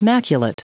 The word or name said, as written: maculate